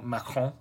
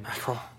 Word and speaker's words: Macron: It's headed by Marine Le Pen, who was the person who ran against Emmanuel Macron. – Yes. – Which is how you pronounce his name. – Macron.